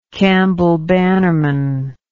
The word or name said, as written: campbell bannerman